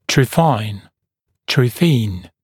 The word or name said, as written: trephine